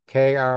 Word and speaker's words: Krakow: Krakow is spelled with a K, Krockow Brewing Company.